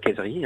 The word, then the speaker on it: Kayseri: Alors la plus grande ville est Kayseri, ou Césarée en français, mais on prononce Kayseri, hein, Césarée en français.